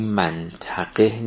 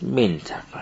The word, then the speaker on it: منطقه: این منطقه نیست، منطقه است، اونم فراغ نیست، فراغ. تلفظ درستش اینه.